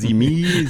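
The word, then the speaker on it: Symi: Genau, das spricht man das, habe ich das jetzt Simi. Richtig, oder heißt das Simi? – Simi, habe ich immer gesagt.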